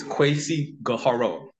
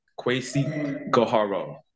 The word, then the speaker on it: Kweisi Gharreau: The proper pronunciation of my name is Kweisi Gharreau. Kweisi Gharreau.